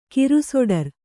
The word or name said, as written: ಕಿರುಸೊಡರ್